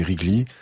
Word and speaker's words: Wrigley: c'est Wrigley. Alors, Wrigley, ça s'écrit W-R-I-G-L-E-Y.